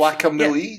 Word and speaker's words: Guacamelee: It's like a melee fighting. It's, guacamelee. Yeah, melee. It's pronounced melee. And then guacamole. Guacamole. Guacamole. Guacamole. Guacamelee.